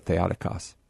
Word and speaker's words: Theotokos: Oh, and that's why I go with Christotokos and then Theotokos is because I know where the accent there is. So anyway, just a little Greek trivia for those who might be interested. That's why I pronounce it Theotokos.